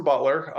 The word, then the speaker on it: Beutler: Yeah, so name is Jason Beutler. The last name is spelled weird. It's got an E in there. We say it's spelled B-E-U-T-L-E-R.